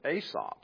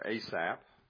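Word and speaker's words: Asaph: It is a psalm of Aesop, or Asap. It's pronounced a lot of different ways, depends on how southern you are, I guess how you pronounce that.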